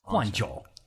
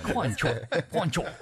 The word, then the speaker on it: Guangzhou: Guangzhou. – Wow. I'm working on that one. – Guangzhou.